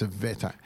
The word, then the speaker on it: Evita: I'd like to say hello to my mate Henry, who is over in Slovakia at the moment with his sweetheart Evita, or Evita it is. I say that wrong every time, but it's Evita.